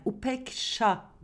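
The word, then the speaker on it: upekṣā: Ve Buda'dan gelen bu kavram aslında Sanskrit dilinde upeka deniyor. Ya, yani Upeksha diye okunuyor.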